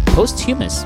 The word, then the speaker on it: posthumous: Post Humus. Humus? Posthumous. Oh, posthumous. It's supposed to be like posthumous.